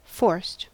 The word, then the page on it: forced: American English